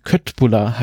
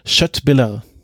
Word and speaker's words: Köttbullar: Es geht wieder um ein Fleischgericht, nämlich kleine Fleischklöße aus Rindfleisch, die, wenn man ihn jetzt unwissend aussprechen würde, den Namen Köttbullar heißen. Aber ich habe mich davor ausreichend informiert und ich werde jetzt mal versuchen, das richtig auszusprechen, nämlich Köttbullar.